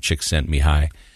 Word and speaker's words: Csikszentmihalyi: In fact, I didn't learn how to say his name. Mihai is his first name, Csikszentmihalyi. I didn't learn how to say that by reading it because it's impossible. I had to go on like the Google Translate and they pronounce it for you out loud. So I just memorized how it sounds.